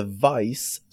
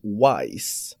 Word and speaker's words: Vice: Hans engelska uttal var och är fortfarande katastrofalt dåligt, så han uttalade Vice som Wise.